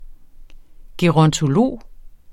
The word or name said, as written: gerontolog